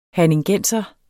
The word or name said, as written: herningenser